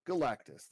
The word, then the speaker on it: Galactus: we have to throw him out there is Galacticus. Can I say that right? – Galactus, there it is.